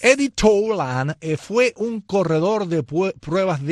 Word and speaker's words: Tolan: En 1908, un día como hoy, nació Eddie Tolan, o Tolan, no sé cómo se pronuncia, Teudi. Ninguna de las dos, como dije, ni Tolan, ni Tolan. Tolan.